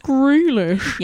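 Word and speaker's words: Grealish: Yeah, I think you'll find it was pronounced Greylish.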